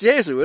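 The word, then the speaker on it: Iesu: Yezu, I'm not giving the whole title, but Yezu, it's pronounced Yezu, and it's sung that way.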